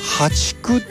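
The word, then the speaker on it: 淡竹: でこれからはね、ハチクっていうね、淡い竹と書いてね、淡いはあの淡水魚の淡ですね、淡い竹と書いてハチク、そして、今月末にはマダケといった種類が芽を出してくるということなんですね。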